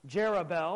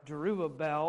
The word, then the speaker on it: Jerubbaal: You see in chapter 9, verse number 1 tonight, it says, "And Abimelech, the son of Jerubbaal," or Jerubbaal, or however you would choose and like to see it. You can pronounce it either way and be correct.